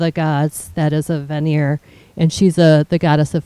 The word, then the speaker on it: Vanir: A Vanir is how it's pronounced.